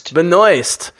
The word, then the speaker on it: Benoist: Benoist.